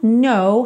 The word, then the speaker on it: know: Então, come up to meet you, tell you I'm sorry, you don't know how lovely you are. Então, aqui, percebe que o no, esse K é mudo, então você está vendo ele escrito ali, mas na hora de pronunciar ele não soa, então you don't know how lovely.